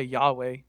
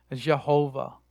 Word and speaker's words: Yahweh: The J-E-D-P hypothesis. You have the J, which is, we would say Yahweh, but the Germans say Jehovah. That's where we get the Jehovah from. That's the German attempt to pronounce Yahweh with the wrong letters, which goes back to some Hebrew stuff.